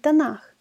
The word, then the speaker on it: Танах: Подчеркните в вашей Танах, Библия Танах, правильно говорить Танах, подчеркните, для всех, кто послушен ему.